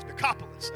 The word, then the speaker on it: Decapolis: Decapolis, there it is, Decapolis.